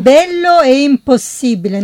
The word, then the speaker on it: Bello e impossibile: Ha sbagliato, falsch. – Falsch, warum? – Bello e impossibile, no impossible. – Ah, die Aussprache.